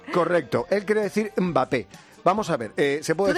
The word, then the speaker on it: Mbappé: A ver, puedes decir Mbappé, que empieza con M, no con E, o Mbappé, que te lo va a permitir cualquiera.